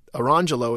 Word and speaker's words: Orangejello: And that's spelled, Arangelo is spelled O-R-A-N-G-E-J-E-L-L-O.